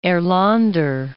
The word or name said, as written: Erlander